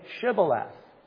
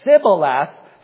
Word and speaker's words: Shibboleth: If he said no, then they would say to him, "Then say Shibboleth." It's kind of like a fountain of springs. And he would say Sibboleth, for he could not pronounce it right.